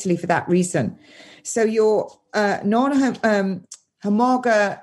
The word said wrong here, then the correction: homologous: I keep saying it wrong. It's really difficult to say. Homologus and joining homologous, your non homologous end jo- uh, and joining engine is working normally.